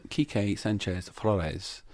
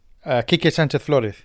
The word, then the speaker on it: Quique Sanchez Flores: 8 games into the season, 8 games under the stewardship of one Quique Sanchez Flores. Did I say that right, with your Spanish expertise? – You did it right. – Here, you do it, quickly. – Quique Sanchez Flores.